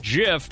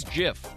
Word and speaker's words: GIF: I was just listening to a show recently, and I noticed that Violetta said the word GIF, but pronounced it like GIF from Jiffy Peanut Butter. Mm-hmm. I always thought it was pronounced like gift with a T.